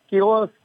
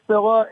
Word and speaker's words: Scyla: Skyla, Scylla, Scylla, S-C-Y-L-A.